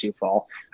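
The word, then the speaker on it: Coufal: Name is spelled Kufal, pronounced Soufal.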